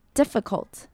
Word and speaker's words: difficult: 最后我们来看到 difficult，要在这边注意的是 i 两个 i 的发音都是要短而急促，尤其是 f 后面接的那个 i， difficult。